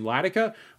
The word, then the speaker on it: Ladika: You can follow my personal Twitter account at Ryan Latica. My last name is spelled L-A-D-I-K-A.